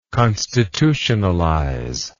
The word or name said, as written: constitutionalize